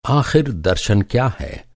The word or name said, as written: आख़िर